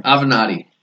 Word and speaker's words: Avenatti: Seriously, how do you say his name? – Avenatti. – Avenatti. Michael Avenatti.